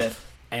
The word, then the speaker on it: Opus Dei: it has this paragraph that like describes what Opus Dei is. I think that's how you say it, right? Opus Dei, is that the organization? – Opus Dei.